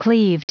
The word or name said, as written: cleaved